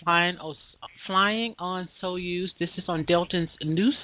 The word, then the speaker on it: Soyuz: Soyuz, yes.